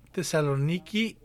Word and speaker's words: Tesaloniki: El texto contesta rápidamente, fue escrita a la iglesia en Tesalónica, Tesaloniki, creo se pronuncia hoy, en Macedonia moderna.